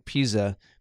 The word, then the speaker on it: Puehse: It's pronounced uh, Pisa.